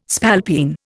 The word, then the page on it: spalpeen: Indian